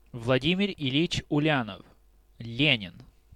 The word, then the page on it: Влади́мир Ильи́ч Ле́нин: Vladimir Il’ič Lenin; [vlʌˈdʲimʲɪrʲ ɪˈlʲjɪʨ ˈlʲenʲɪn]; Russian